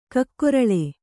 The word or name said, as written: ಕಕ್ಕೊರಲೆ